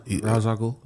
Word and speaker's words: Ra's al Ghul: he goes and fights Ra's al Ghul, right? Or I think I'm saying that wrong. – Yeah, Ra's al Ghul.